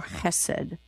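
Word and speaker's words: chesed (חסד): And so we want you to have the sound of a het here and not just a plain H. Now, the book that we're offering, it spells it H-E-S-E-D, but it can be with a dot under it. Then people who know this rule will pronounce this with the ch sound for chesed.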